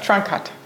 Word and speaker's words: Trunkhut: Seit mein Mann Trunkhut, wie spricht man es eigentlich richtig aus? – Trunkhut. – Trunkhut. Okay.